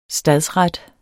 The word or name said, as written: stadsret